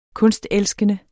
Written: kunstelskende

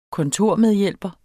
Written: kontormedhjælper

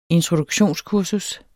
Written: introduktionskursus

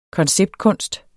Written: konceptkunst

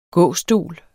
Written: gåstol

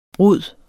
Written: brud

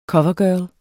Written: covergirl

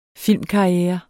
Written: filmkarriere

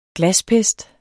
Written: glaspest

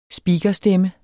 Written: speakerstemme

